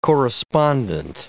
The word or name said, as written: correspondent